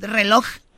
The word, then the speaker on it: reloj: Los nacos al reloj nunca le dicen la J, nunca mencionan la J al final, ¿verdad? Reloj.